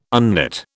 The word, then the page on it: unknit: British English